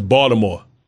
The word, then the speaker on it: Baltimore: It's Baltimore. – Baltimore? – You don't pronounce the A. It's not Ball. It's Baltimore.